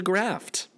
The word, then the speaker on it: graft: So the current standard for repairing bone is a graft. Harvesting bone like XY graph? – Yeah. No, graft with a T. You didn't pronounce the T.